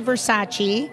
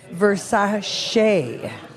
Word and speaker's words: Versace: So we say Versace. – That's wrong. – What apparently. Does she say? – Versace.